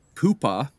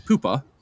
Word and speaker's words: Poohpha: But reading his pronunciation guide here, Pupa is his first name, and his last name is War-a-cool-naro. So if you want the spelling behind this, it is P-O-O-H-P-H-A, that's Pupa. And then his last name, W-A-R-A-K-U-L-N-U-K-R-O-H.